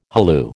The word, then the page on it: halloo: American English